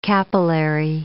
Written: capillary

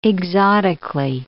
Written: exotically

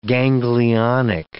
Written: ganglionic

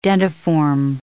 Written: dentiform

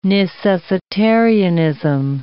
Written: necessitarianism

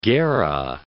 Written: Gera

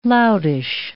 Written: loutish

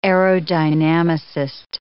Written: aerodynamicist

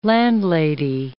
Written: landlady

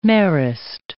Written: Marist

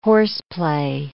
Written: horseplay

